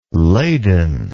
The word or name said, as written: Ladon